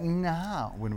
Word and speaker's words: Gnarwin: Let's talk about Narwin Road with a silent G, because otherwise it'd be called Gnarwin Road.